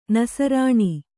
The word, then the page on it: ನಸ್ರಾಣಿ: nasrāṇi